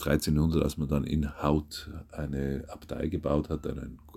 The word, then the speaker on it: Howth: Auf jeden Fall ist diese Kirche genutzt worden als Pfarrkirche auf der Insel für den Ort Howth, oder eigentlich spricht man in Haut aus, habe ich heute gelernt.